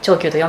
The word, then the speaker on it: 長久: はい、日本酒がまあ一番作りたかったものなんですね、はい、今はそうで作ったのは長久という、はい、まあ、長く久しいと書いて長久と読むんですけども、うん、